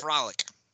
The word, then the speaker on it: Fralic: So Jr. and Bill Fralic, is that how you pronounce this shit, Fralic? Mm-hmm. All right, I just want to make sure that I'm pronouncing. – I believe it correct. it's Frolic. – Frolic.